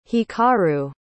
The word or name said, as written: hikaru